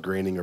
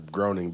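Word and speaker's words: Groening: Well, it's that groaning thing, too, or I don't know if it's pronounced graining or groaning, but... – Graining. – Graining.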